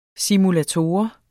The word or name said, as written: simulator